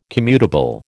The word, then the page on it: commutable: American English